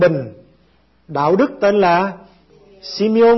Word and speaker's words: Simeon: Simeon, bây giờ chúng ta đọc là Simeon.